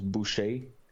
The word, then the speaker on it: Boucher: That's why I called myself the Butcher, because it's actually a French name that means butcher, but it's actually pronounced Boucher.